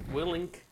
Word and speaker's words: Willink: We were covering uh, Way of the Warrior Kid by Jocko Will- Willink. – I always say his last name wrong. I always miss the N.